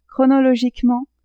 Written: chronologiquement

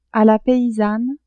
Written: a la paysanne

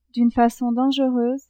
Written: d'une facon dangereuse

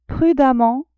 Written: prudemment

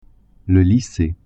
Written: lycee

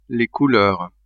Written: couleurs

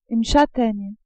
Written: chataigne

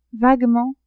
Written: vaguement

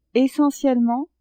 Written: essentiellement

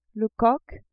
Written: coq

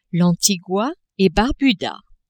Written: Antigua-et-Barbuda

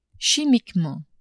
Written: chimiquement

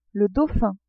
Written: dauphin

